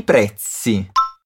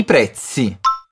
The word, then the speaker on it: prezzi: I prezzi. Capricha bem no som do Z. É a mesma pronúncia do Z na palavra pizza. Mais uma vez. I prezzi.